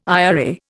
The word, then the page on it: IRA: Indian